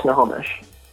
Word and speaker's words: Snohomish: It's called Snohomish. – Snohomish.